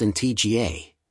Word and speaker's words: Antigua: Antigua, pronounced in TGA, and Barbuda, Barbuda, is located in the heart of the Caribbean Sea.